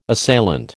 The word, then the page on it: assailant: American English